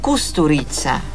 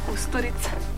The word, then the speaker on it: Kusturica: ¿Cómo se pronuncia? No es Kusturica, ¿cómo es? – Kusturica. – Kusturica.